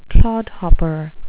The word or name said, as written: clodhopper